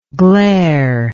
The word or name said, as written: glareless